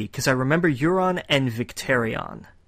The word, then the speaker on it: Euron: And I know some people pronounce it differently. Get over it. It's pronounced Euron.